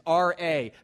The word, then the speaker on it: Ra: Think about the first two letters of his name, Ramses, R-A. How do you pronounce that?